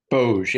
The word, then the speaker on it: Bozh: So, Warden Boj, or Boj. Not sure if I'm pronouncing it. – Boj, yeah, B-O-Z-H. – Z-H. Okay, there we go.